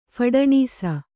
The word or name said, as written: ಫಡನೀಸ